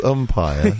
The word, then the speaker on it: umpire: 4-6 umpire. – Yeah, I didn't know really how to finish that cricket haiku. – I would also contest that the word umpire is two syllables, not three.